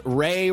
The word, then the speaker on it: Wray: He says Wray rhymes with gay.